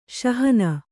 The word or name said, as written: ಸಹನಾ